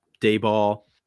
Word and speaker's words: Daboll: Daboll. Did I pronounce it right that time? – Yes, you did. Yeah, yeah. Oh, okay. It's not Daboll or Daboil Ball.